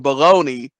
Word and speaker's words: Bologna: But if you look at, like, pony and baloney, which is spelled Bologna, right. – And that'll be the thing. It's like he'll read it. He'll be like, Bologna. And I'll be like, yes, but no.